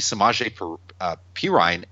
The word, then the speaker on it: Samaje Perine: And with Rob Kelly out, rookie Samaje Perine ended up having 21 carries.